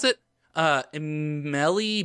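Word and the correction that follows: Émile: Borel. Sure. Uh, Emile. Emile. Oh, the.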